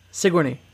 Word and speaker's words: Sigourney: Yeah, but you're putting too much emphasis on the Sig part. It's just Sigourney. – Sigourney.